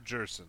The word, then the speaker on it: Gerson: which means I have to figure out how to say his name, Gerson Bautista? Gerson? Like person? – I have heard Gerson. – Gerson. – That is what the Mets Gerson. – Minor league announcers said.